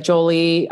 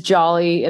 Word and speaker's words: Jolley: It is jolly. It rhymes with trolley and it mm-hmm. – definitely has the ey at the end.